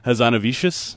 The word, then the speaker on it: Hazanavicius: Hazanavicius.